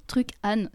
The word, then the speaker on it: Truc Anne: Alors ça s'écrit Truc Anne, ce qui est assez marrant et pratique pour la mémoire.